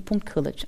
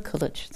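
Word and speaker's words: Kılıç: Und im türkischen Alphabet, da gibt es das I ohne Punkt. Mhm. Und also wohl Asli, also Asle, wird ohne I-Punkt geschrieben und Kilic, auch ohne I. Kilic.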